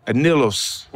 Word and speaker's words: anillos: Anillos.